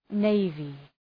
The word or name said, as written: navy